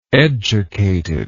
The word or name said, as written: educated